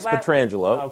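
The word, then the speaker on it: Pietrangelo: For Vegas, Mark Stone, How do you say Alex? – Alex Petrangelo. – Okay, good.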